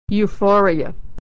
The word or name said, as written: euphoria